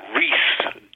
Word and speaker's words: Rhys: How is your name correctly pronounced? – It is correctly pronounced John Rhys Davies.